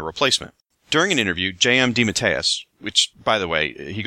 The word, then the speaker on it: DeMatteis: And yes, that is apparently how you pronounce his last name. I asked him on Twitter. It's DeMatteis.